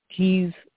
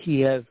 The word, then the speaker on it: Kyiv: Kyiv, which is the way they pronounce it in Ukrainian as opposed to Kiev, which is Russian.